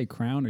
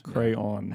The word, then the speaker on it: crayon: I go all the way with it. Crayon. – Yeah. I know that's the right way to say it. – It's two syllables.